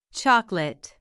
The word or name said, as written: chocolate